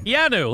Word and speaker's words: Keanu: Keanu, like Keanu Reeves.